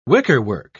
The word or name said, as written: wickerwork